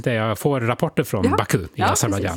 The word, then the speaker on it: Baku: Man säger inte Baku, man säger Baku. – Ja, på, med en försvenskat uttal.